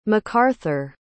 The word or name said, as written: mcaurthur